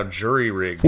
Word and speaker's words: jerry-rigged: Well, so it's often pronounced now jury-rigged because people think it has something to do with rigged juries, but jerry-rigged actually. Nope, different two. Jerry-rigged.